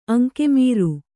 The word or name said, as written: ಅಂಗಮಾರಿ